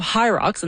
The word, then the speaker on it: Hyrox: Now, High Rocks, and that's spelled H-Y-R-O-X, first launched in Germany in 2018.